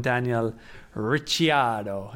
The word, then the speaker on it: Ricciardo: Now the problem is how I was pronouncing it was also wrong. So I'm now going to have to call him Daniel Ricciardo. And you have to say it like that as well.